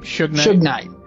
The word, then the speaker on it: Suge Knight: Suge Knight.